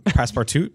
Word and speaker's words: Passepartout: Passportout. That's French. – Okay, so Passportout is this wonderful Passportout. Passportout. – The T is silent.